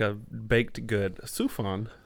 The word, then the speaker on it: Sufjan: Little, how you say it? Spooshjan? Spooshjan Stevens? – Whoa. Oh. – I thought it was Surf John. – I don't think it's Surf John. Sufjan Stevens? – Sufjan. There you go.